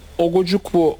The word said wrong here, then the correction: Ugochukwu: olunca, doğru mu telaffuz ettim? – Ugochukwu hatta. – Leslie diye gitsin ya. – Herkes öyle diyor zaten. – Leslie. – Leslie diye. – Doğru. – Ugochukwu.